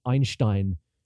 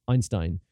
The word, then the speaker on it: Einstein: And again, 40% of the time, the AI says Einstein, which no English speaker says unless they're a prick, basically. Unless they're a prick who, you know, who wants to show off how great their German pronunciation is. But the fact is, you know, yes, Einstein may have been the guy's name, but the way it's said in North American English is Einstein.